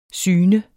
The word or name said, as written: syn